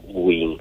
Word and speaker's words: Guin: e despois de Goín pasou a Guin, porque o que temos falado xa moitas veces nesta sección de que os xos tenden a pechar, entón por iso se pronuncia Guin actualmente, ¿no?